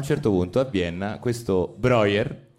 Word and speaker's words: Breuer: C'è Joseph Brauer, penso si, pronunci, si pronunci così. Breuer, Breuer, ecco, vedi, Breuer, vabbè, perdonatemi, è tedesco.